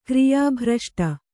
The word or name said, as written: ಕ್ರಿಯಾಭ್ರಷ್ಟ